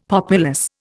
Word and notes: populous: Indian

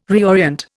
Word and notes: reorient: Indian

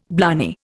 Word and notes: blarney: Indian

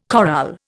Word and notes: chorale: Indian

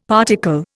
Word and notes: particle: Indian

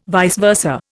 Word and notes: vice versa: Indian

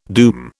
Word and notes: doom: British English

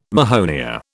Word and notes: mahonia: British English

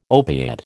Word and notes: opiate: American English

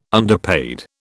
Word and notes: underpaid: British English

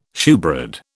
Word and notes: shewbread: British English

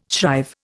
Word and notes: shrive: Indian

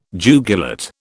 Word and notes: jugulate: British English

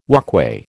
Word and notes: walkway: American English

